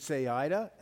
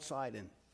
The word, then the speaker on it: Saida: In Arabic, they now spell and pronounce it Saida, S-A-I-D-A, although many still call it Sidon.